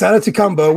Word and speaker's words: Adetokunbo: It's Adetokunbo, which his mother appreciates. Everyone, we shortened it, you know, you shortened it down to Ade, but I know his mother appreciates him being called Adetokunbo.